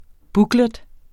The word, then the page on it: booklet: ˈbuglεd; bugˈlεd